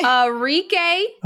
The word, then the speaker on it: Arike: Arike.